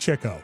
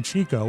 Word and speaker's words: Chico: Chico Marx, his name should be pronounced Chico. That's how they did it. But okay. – I, my entire life, have called him Chico, which I guess is what? The Spanish pronunciation.